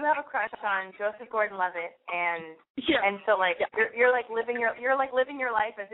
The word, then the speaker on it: Gordon-Levitt: Joseph Gordon-Levitt, yeah.